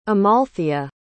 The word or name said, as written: amalthea